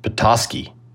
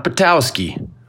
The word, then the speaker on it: Patoski: Fascinating guest this week, Joe Nick Patoski. Not Patoski. I always pronounced it Patoski, but Joe Nick quickly corrected me when we started this podcast, which you'll hear.